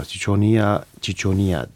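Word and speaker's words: Ciconia ciconia: Chichonia, Chichonia diye okunuyor bildiğim kadarıyla.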